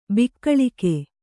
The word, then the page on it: ಬಾಕುಳಿಕ: bākuḷika